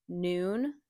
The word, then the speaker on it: Nuun: But those are my two kind of, like, top options, is Noon. So again, that's spelled N-U-U-N. And then Element, which is spelled L-M-N-T.